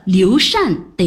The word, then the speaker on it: 刘禅: 哦，对了，刘备的儿子刘禅得读作禅。 – 你对他的认识有多少啊？ – 还行吧。今天我给你上一堂历史课好了，洗耳恭听。 – 刘禅是蜀汉孝怀帝，又称后主，什么意思呢？